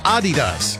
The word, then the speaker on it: Adidas: Americans pronounce Adidas wrong. It's actually supposed to be Adidas, with a slight emphasis on the first syllable, not like I first pronounced it. We all say Adidas, stressing the second, but it's a little bit on the first, Adidas.